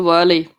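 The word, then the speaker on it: Wehrle: Cole Whirl, oder wie spricht man den aus? – Cole Whirly.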